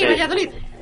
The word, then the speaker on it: Valladolid: ¿sí lo dije bien? – Sí, Valladolid. Eso es correcto, muy bien.